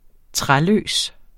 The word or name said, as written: træløs